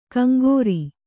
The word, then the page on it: ಕಂಗೋರಿ: Kannada